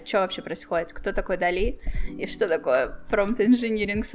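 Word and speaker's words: DALL-E: Нужен ли вообще промпт инжиниринг, как ты думаешь, если написание промптов станет простым с Дели? Дали, как он правильно пишется? Читается. – Дали, Дали, Дали, Дали.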